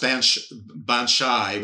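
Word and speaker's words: baobhan sith: But basically, it's pronounced Banshai, which is a banshee.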